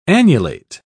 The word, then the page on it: annulate: British English